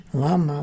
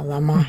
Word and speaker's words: лама: Ударение так ставят там. Не лама, а лама.